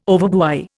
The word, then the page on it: overbuy: Indian